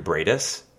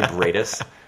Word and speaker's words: Briedis: Hi, my name is Andrew Briedis, not Andrew Keenan Bulger. My name is Andrew Briedis. That's spelled B-R-I-E-D-I-S.